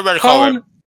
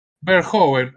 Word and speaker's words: Verhoeven: Paul Verhoeven. Verhoeven, ahí está, bueno, ok.